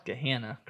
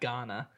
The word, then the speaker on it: Gahanna: For our beer of the week, we go to the suburbs of Columbus, Ohio, to, correct me if I'm pronouncing this wrong, Gahanna, Ohio? – Gahanna, correct. Not Ghana. – Gahanna? – Or whatever Gahanna? You were saying, yeah. – Like Louisville. Louisville, Ghana, Ghana. – Ghana.